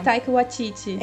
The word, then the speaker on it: Taika Waititi: Ah, é Taika Waititi. – É isso? isso. Ah, isso. não sabia pronunciar. o Taika Waititi.